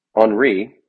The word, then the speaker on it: Henry: Henri, it's actually spelled like Henry, had not been sworn in when the president was killed, but that process had already begun.